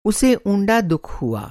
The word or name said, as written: ऊँड़ा